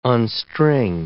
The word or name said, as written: unstring